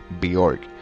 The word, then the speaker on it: Björk: Llamada Bjork, pero se escribe B de bueno, J O R K. Bjork, pero se pronuncia Bjork.